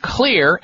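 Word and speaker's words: Xlear: And the one for allergies is called Clear, and Clear is spelled with an X, X-L-E-A-R.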